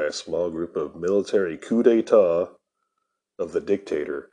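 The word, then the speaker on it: coup d'état: First thing I want to talk about is a coup d'etat.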